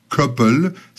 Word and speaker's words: Cauple: Et le résultat de cette belle union, de cette fusion, si tu veux, s'appelle maintenant Couple, C-A-U-P-L-E, qu'en français, on peut parfaitement prononcer couple.